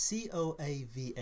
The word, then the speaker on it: Coava: How do you say it? Coava? – Yes, it's Coava. – Okay. – I never know if I pronounce it right, but I've come up with Coava.